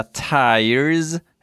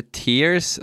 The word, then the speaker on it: tiers: Det finns lite olika tires eller tears som vissa säger, vilket jag tycker låter jättekonstigt eftersom det uttalas, eh, stavas T-I-E-R-S.